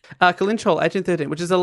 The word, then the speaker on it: Kellynch: Kalinch. – Kalinch. It's spelled Kelly, K-E-L-L-Y-N-C-H.